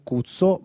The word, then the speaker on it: KUTSO: Allora, innanzitutto per gli ascoltatori il nome si chiama Cazzo o Cuzzo, ma si scrive KUTSO.